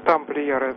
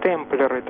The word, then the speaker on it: темплеры: Вы все-таки неправильно сказали тамплиеры. Там они произносятся как темплеры, там вы одну букву лишнюю.